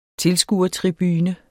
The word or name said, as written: tilskuertribune